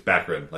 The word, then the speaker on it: Baccarin: During one of the segments, she was like, it's Baccarin, like saccharin.